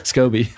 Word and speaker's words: SCOBY: I have a live Scooby. – Scoby. – How do you say it? Scoby? – Yes.